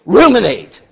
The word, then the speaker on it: ruminate: That he would go, and you said it one time, I got to get it right, sheep remunerate, is that it? Where they burp it up? Ruminate. I knew I was saying it wrong. Ruminate.